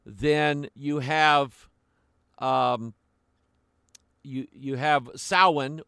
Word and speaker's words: Samhain: The M there is silent. It's pronounced Samhain.